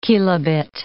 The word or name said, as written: kilobit